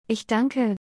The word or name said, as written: ich danke